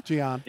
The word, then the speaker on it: Gyan: Listen, I was excited about watching the Chinese mob, Shanghai SIPG, and the man on 23 million, Asamoah Gyan, or however you pronounce his name, the man from Ghana. – Gyan.